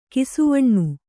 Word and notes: ಕಿಸುವಣ್ಣ: Kannada

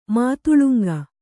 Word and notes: ಮಾತುಲುಂಗ: mātulanga

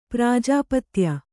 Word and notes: ಪ್ರಾಜಾಪತ್ಯ: prājāpatya

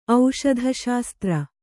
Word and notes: ಔಷಧಶಾಸ್ತ್ರ: auṣadha śastra

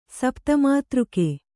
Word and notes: ಸಪ್ತಮಾತೃಕೆ: Kannada

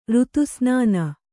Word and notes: ಋತುಸ್ನಾನ: Kannada

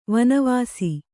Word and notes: ವನವಸಿ: vana vasi; Kannada

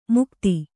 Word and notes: ಮುಕ್ತಿ: mukti

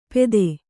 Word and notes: ಪೆದೆ: Kannada

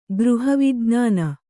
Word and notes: ಗೃಹವಿಜ್ಞಾನ: Kannada

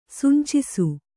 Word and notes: ಸಿಂಚಿಸು: Kannada

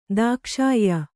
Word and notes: ದಾಕ್ಷಾಯ್ಯ: dākṣāyya

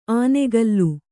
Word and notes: ಆನೆಗಲ್ಲು: Kannada

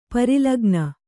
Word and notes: ಪರಿಲಗ್ನ: pari lagna

